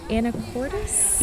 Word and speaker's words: Anacortes: Anacortes? – Yeah, good job.